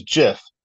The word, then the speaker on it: GIF: It said he called it a GIF with a soft G. Choosy developers, he said, choose Jif.